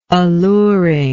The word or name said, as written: alluring